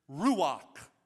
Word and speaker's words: ruach: So, the Hebrew word for the Holy Spirit or Holy Ghost is this word, ruach. Ruach. Now, when you say that word, both campuses, you have to say it like you got some steak stuck in the back of your throat, like that.